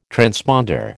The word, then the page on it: transponder: American English